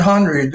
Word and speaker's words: Henreid: Again, it's We Love Paul, H-E-N-R-E-I-D. Follow her on both. – I think he pronounced it Henreid, didn't he? – It's Henreid.